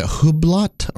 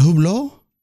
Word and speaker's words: Hublot: Is it Hublot? – Hublot, I think. – Oh. – Yeah. I think it's the French pronunciation.